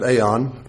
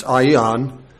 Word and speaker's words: aeon: We sometimes pronounce it aeon, but it's aeon.